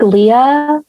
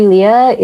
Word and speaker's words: Leia: I think Leah, as far as Judy Leia, is pretty straightforward, especially if you're like Star Wars fan, right? Like Princess Leia, it's spelled that way.